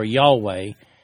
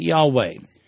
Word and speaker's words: Yahweh: And that's why some people today pronounce it Jehovah, while others pronounce it Yahweh. Technically speaking, however, there is no J sound in Hebrew. Those words actually begin with a hard Y consonant.